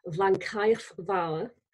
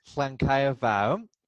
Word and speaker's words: Llancaiach Fawr: Llancaiach Fawr Manor House. – Llancaiach Fawr is a place in, it's a Tudor manor in Nelson, which is in the Rhymney Valley in South Wales.